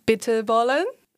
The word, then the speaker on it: bitterballen: For example, bitterballen. I don't know if I pronounce well. Or the thing is, like, nobody in this studio at the moment is from the Netherlands, but as far as I know it, it's pronounced exactly like you did.